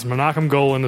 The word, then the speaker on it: Menachem Golan: The only reason why I know how to pronounce Menachem Golan is because I watched the documentary Electric Boogaloo, The Story of Canon Films, which is a fantastic documentary.